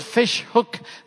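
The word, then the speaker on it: Vishoek: But I live in a little place called Fishhook. That's not spelled with two O's. It's spelled with an E, which means fish corner.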